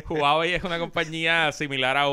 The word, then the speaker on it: Huawei: Hay una compañía china de eh, celulares que se pronuncia Huawei.